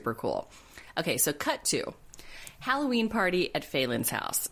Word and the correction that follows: Fallon: Now, before this happens, we just have to make mention. Fallon. I have it written out phonetically the wrong way in my notes. It's Fallon.